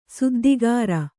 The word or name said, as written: ಸುದ್ದಿಗಾರ